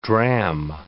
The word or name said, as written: drachm